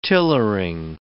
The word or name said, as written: tillering